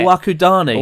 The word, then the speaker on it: Owakudani: I went to Owakaduni..." I can't pronounce that. That was awful. I went to Owakaduni. I love it. – Owakudani.